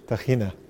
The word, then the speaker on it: tahini: Tegina, ja, dat is eh, tahini oh, ook tahini. wel in Nederland. Ja, ah, tahini. tahini. – Ja, maar dat kun je ook uitspreken als... Tagina.